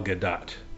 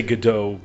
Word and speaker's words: Gadot: Yeah, My understanding is it's pronounced Gal Gadot. That, you know, people say Godot, but that would be a French pronunciation, and she's not French.